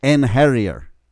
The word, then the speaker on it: Einherjar: It's Einherjar. – Yes, it is.